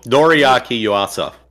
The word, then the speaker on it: Noriaki Yuasa: I think it's, Noriaki Yuasa.